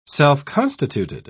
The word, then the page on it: self-constituted: British English